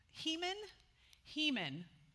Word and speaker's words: Heman: Heman, but it's He-Man, is how it's spelled.